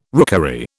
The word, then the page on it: rookery: British English